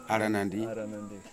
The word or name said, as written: arenandi